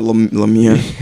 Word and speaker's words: Lemieux: What did I say? I said Lemieux. there's a silent R. Or no, there's... X.